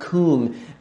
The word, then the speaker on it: cwm: Coom, the deeping coom, it's Celtic for deep valley. It shows up, it's spelled C-W-M in Welsh, but it's in parts of Mount Everest are called the coom and so forth, where you would stop.